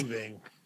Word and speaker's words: Yngwie: It's Yngwie. It's Yngwie. – When people see his name, they don't know how to say it. They're like Ying Yong Wingwe.